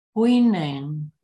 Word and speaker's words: Hui Neng: Is that is like a Wei, H-U-I? – Well, you just moved the page. Oh, no, it's right here, yes. Hui Neng? – Yeah. – Hui Neng? – Yeah. So H-U-I is like Wei.